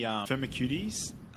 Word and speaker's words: Firmicutes: Yeah, when you're mentioning the Firmicutes, remember there was another one of my mentors, he used to say, like, that they don't make you firm and cute, even though they're spelled like that.